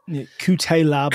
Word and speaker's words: Cutelaba: Cutelaba, is that how you pronounce his name? Yeah. – Cutelaba.